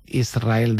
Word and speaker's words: Israel: Y la S con la R es difícil de pronunciar. La gente dice Israel, es verdad. – Que suene la S.